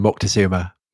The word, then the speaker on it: Moctezuma: I would call him Moctezuma. – Would you? You're so much woker than I am, Tom. – Is that woker? – I think it's more Spanish. – It's a Spanish pronunciation, isn't it?